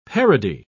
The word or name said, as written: parody